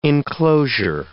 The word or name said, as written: enclosure